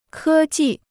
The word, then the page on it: 科技: kē jì